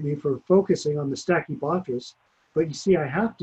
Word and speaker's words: Stachybotrys: I always say it wrong. What's wrong with me, man? What is it? Stinky butt ass? – Stachybotrys. – Stachybotrys. There you go.